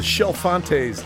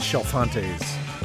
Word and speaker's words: Chalfontes: The Shel Fontes did He Loves Me, and I know how to pronounce that because there's a handy pronunciation guide on the label. But it's spelled, you know, C-H-A-L-F-O-N-T-E-S, The Shel Fontes.